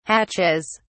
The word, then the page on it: hatches: British English